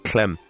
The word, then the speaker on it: CLM: but a pseudonymous beatmaker operating under the pseudonym CLM, spelt all caps and, according to his SoundCloud, pronounced Clem.